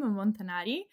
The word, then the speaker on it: Montanari: Perfect pronunciation for my surname, Montanari.